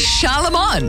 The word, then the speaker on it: Charlemagne: It's Charlemagne.